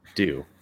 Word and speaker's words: Due: And if you are a USL broadcaster, that is how you pronounce his last name. It's Due.